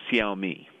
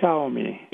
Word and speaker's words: Xiaomi: If you want to introduce Americans to the world's new third biggest smartphone maker, you got to master its name. – I like to pronounce it Xiaomi. – Xiaomi.